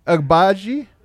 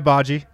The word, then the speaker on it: Agbaji: Yeah, there's Ochai. Agbaji? – That's correct. Ochai Agbaji.